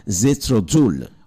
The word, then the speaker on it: Jethro Tull: Pues en 1947 nace este hombre y es el líder de una banda que se llama Jethro Tull.